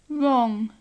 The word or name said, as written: wrong